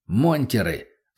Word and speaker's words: монтеры: Монтеры», — слово он произнес с ударением на первый слог, — «лютовать стали не по-детски».